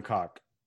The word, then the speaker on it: Koch: Actually, it is Heckler and Koch. – Yep. – Oh, of course. I should have known. – It really is. I love dick jokes, but that's actually how it's pronounced.